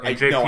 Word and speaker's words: NJPW: And that is the story arc where he decides to portray NJWP. Or, NJPW. I said that wrong. – NJPW. You always said it wrong when we were watching the show, so at least you're consistently wrong.